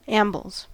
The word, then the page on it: ambles: American English